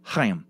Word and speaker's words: Chaim: Chaim Weitzman's first name and mine is pronounced Chaim, with a sort of throat-clearing sound at the beginning.